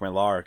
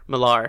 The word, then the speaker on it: Millar: I'm even saying his name wrong. I'm sorry. – Millar. – Yeah. That's how you say it? – Millar, yeah.